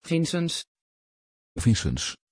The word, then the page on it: vincenz: Dutch